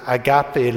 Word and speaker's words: agape: And the love that he's talking about is the word, it's pronounced differently here than in Europe, is agape love.